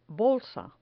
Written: bolsa

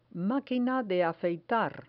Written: maquina de afeitar

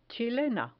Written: chilena

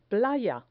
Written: playa

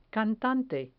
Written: cantante

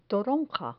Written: toronja

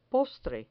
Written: postre

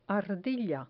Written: ardilla

